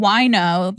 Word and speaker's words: Wojno: Like, yeah, but it makes more sense than Wino. – Yes. – It was spelled Wojno.